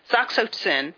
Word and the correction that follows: oxytocin: I'm sorry, it's oxytocin is how you pronounce that.